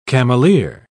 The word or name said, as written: cameleer